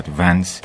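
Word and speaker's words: Vance: سایروس فانس، یا آنطور که گاهی تلفظ می‌شود ونس، در دوره‌ای معاون جیمی کارتر ریاست جمهوری آمریکا بود.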